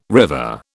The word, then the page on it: river: British English